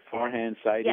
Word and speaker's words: Farhan Zaidi: The Farhan Zaidi, am I saying his name right? Farhan Zaidi? – Yes. Yes.